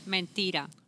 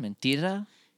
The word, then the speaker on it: mentira: Mentira. – Mentira? With an R at the end? Mentira? – Yes, it's a feminine word.